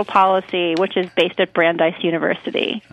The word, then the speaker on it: Brandeis: Brandeis University, okay. – Yeah. – Brandeis University.